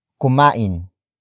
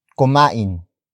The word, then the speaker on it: kumain: Sila is spelled as S-I-L-A. It means they, which is also the focus of this sentence. Followed by kumain, kumain, kumain. Kumain is spelled as K-U-M-A-I-N.